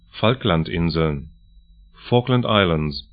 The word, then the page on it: Falklandinseln: 'falklant-ɪnzln